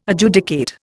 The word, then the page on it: Adjudicate: Indian